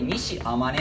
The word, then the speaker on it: 西周: これ西天音っていう人が書いたものです。西周と書いて天音っていうのから読み方読めなかったら、西天音とか、まあ、ひどいやつになると誰が作った聖周と誰中国人レベル中国にいそうだよね、うん、で西天音が作り、